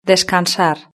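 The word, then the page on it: descansar: Spain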